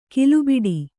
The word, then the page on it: ಕಲ್ಲುಬೆಟ್ಟ: kallu beṭṭa